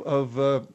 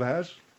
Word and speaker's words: Oz: The German cowboy wore a pointed hat like the Scarecrow in Wizard of Oz? Wizard of Ass? – It's not Wizard of Ass. You know that. – No, I know that. But I didn't know how to pronounce it. Oz, right? – Yes.